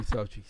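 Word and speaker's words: Xochitl: X-O-C. – H-I-T-E-L. – Sochi. Sochi. Sochi. That's how you say that.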